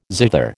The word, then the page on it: zither: American English